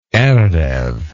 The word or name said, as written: erev